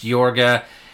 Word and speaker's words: Iorga: It actually was spelled I-O-R-G-A. I think it was intended to be pronounced Yorga, and then they realized people aren't going to know how to pronounce it, so let's just put the Y in front of it.